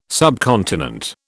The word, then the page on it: subcontinent: British English